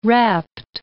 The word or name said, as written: rapt